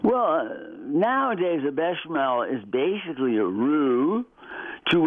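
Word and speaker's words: béchamel: Pardon my French here, bechamel. Am I saying that right? – Yeah, bechamel.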